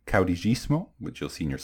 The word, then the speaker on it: caudillismo: So it's pronounced caudillismo, and the leader is a caudillo. So caudillismo and caudillo.